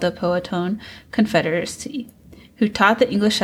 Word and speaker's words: Powhatan: He also developed relationships with Chief Powhatan. Sorry if I didn't pronounce that right. The Powhatan. Leaders. Yeah. Is that how you say it? – Mm-hmm.